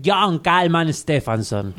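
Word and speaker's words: Jón Kalman Stefánsson: Y ya saben, la trilogía del muchacho de John Kalman Stefansson.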